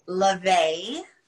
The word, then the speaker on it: Lovay: No, Lovay.